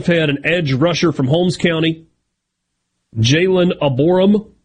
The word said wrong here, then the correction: Abram: a corner from Oak Grove, Gabriel Moore. – Abram. – Huh? – He's just Abram. – I put an extra syllable in there. – Yeah, put an extra O in there.